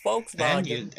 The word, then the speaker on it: Volkswagen: It's Volkswagen. – And you said it in the German pronunciation.